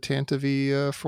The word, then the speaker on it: Tantive IV: That's how they said it on the radio drama. I hear a lot of Tantives lately, but they always said Tantive IV in the radio drama.